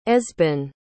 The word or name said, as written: esben